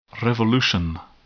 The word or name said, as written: revolution